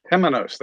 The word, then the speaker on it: Temenos: Am I saying that right? – Tenemos, that's correct.